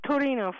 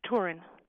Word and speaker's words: Turin: It's not Torino for us. It's Turin.